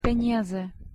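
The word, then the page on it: peniaze: Slovak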